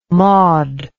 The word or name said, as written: maud